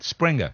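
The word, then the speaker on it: Sprenger: How do you say it? – Springer.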